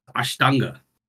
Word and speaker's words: Ashtanga: Bosco, if you want to impress her, you could probably pronounce it, which is Ashtanga.